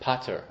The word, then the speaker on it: Peter: That's how it's commonly pronounced. Rabbeinu Peter, which is a lot of theories as to how he got a name like that. Baliatosis did not commonly carry Christian names. Some suggest he was a Bechor, actually, from the expression Peter Rechem. And others say that perhaps it should be pronounced Pater, as if his name was Abba, then it would be Rabbeinu Peter.